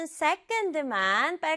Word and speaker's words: second: 세 번째 단어는 second, second 이렇게 두 박자인데요. 앞부분을 강조해서 second, second.